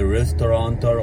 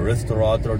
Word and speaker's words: restaurateur: I'm not a restauranter or a restaurateur, depending how you want to pronounce it.